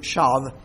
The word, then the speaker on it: shav: If you want to anglicize it, you could spell it S-H-A-V, shav.